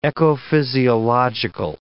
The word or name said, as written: ecophysiological